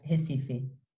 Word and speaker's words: Recife: So you call it Recife, how do you say it? – Yeah, Recife. Recife. Oh yeah, so that's because it's spelled like Recife, which is how I've been saying it the whole time.